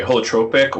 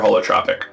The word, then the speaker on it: holotropic: So you can say it either way, holotropic or holotropic. Either is fine.